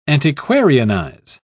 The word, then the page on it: antiquarianize: British English